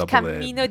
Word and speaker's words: camino: For example, il camino di Santiago with a double M.